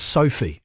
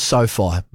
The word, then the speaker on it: SoFi: We will get to Michael Burgess, who is at what's actually been clarified here on the text is pronounced Sophie. Excuse me, Peter. Sophie Stadium. I thought it was SoFi. Maybe I was getting so. Looks like SoFi, doesn't it? Written down.